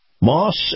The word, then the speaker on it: Maus: Moss Family Automotive, in particular, the Moss Acura Division. That's spelled Moss, spelled M-A-U-S, Acura spelled A-C-U-R-A.